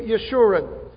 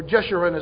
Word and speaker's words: Yeshurun: The difference is in His use of the name Yeshurun, or Jeshurun as some people pronounce it.